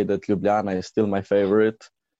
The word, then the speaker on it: Ljubljana: How do you pronounce that properly? Ljubljana. – Ljubljana.